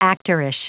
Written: actorish

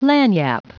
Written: lagniappe

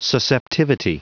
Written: susceptivity

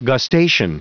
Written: gustation

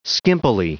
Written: skimpily

